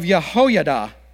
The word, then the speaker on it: Jehoiada: Then Benaiah, the son of Jehoiada, and I looked that up so I could say it right, the son of a valiant man of Kabzeel, who had done mighty deeds, killed the two sons of Ariel of Moab.